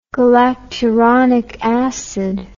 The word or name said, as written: galacturonic acid